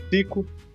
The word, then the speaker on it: Cyco: Então, é, eu sou o Cico. Se escreve com C-Y-C-O.